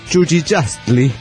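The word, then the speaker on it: Chuchichäschtli: Es Huijucli, que se pronunciaría Chuchichastli.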